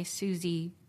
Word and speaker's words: Susy: My Twitter, if you want to follow me, is S-U-S-Y Susie Botello, B-O-T-E-L-L-O.